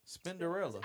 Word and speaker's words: Spinderella: Spinderella.